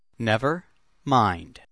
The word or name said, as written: never mind